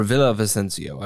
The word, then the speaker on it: Villavicencio: Villavicencio or Villavicencio. I've heard it pronounced both ways, but...